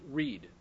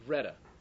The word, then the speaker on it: Wrede: But the first name I want you to write down is William, and we're gonna pronounce it Reed. It's actually Reda, but W-R-E-D-E. But we're going to just Texanize it and call it Reed, but it's Reda.